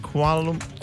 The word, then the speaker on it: Kuala Lumpur: Is it Kuala Lumpur? – Yeah, you said it right.